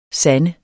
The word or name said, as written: sande